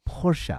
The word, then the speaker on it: Porsche: No, it's Porsche.